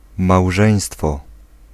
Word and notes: huwelijk: /ˈɦywələk/

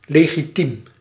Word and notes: legal: American English

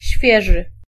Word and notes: nouvelles: [nu.vɛl]

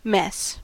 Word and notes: mess: /mɛs/

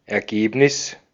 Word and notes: consecuencia: IPA: /kon.seˈkwen.θja/; Spanish

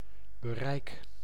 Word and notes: portée: France; [pɔʁ.te]

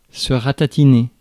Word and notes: ratatiner: France; /ʁa.ta.ti.ne/

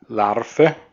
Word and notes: Larve: [ˈlaʁfə]; /ˈlaʁvə, ˈlaʁfə/

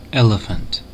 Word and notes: elephant: American English; [ˈe.lɪ.fənt]

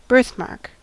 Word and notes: Mal: /maːl/

